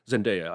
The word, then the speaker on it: Zendaya: Because it's Zendaya.